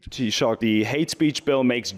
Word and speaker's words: Taoiseach: Taoiseach.